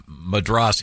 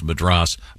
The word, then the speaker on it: Madras: That seems like a fair By the way, Dean just informed me Madras is incorrectly pronounced Madras, but Americans say Madras.